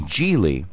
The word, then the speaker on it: Geely: Geely.